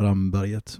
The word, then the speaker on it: Ramberget: Så att även enligt de uttalsregler som finns då, så bör det vara Ramberget.